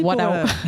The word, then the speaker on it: Wada: Eh ben merci Wada. – En plus, prononcer comme ça, c'est parfait.